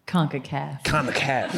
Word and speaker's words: CONCACAF: Did I say that right? – CONCACAF. – Yes.